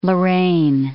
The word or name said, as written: Lorain